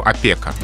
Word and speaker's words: опека: «Афера» и еще одно слово, где часто делают ошибку — «опека». Надо говорить именно так.